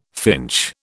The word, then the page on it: finch: British English